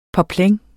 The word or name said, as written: poplin